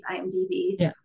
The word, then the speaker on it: IMDb: Is it already on IMDb? – IMDb. – I said that right, didn't I? IMDb. – International Movie Database, IMDb.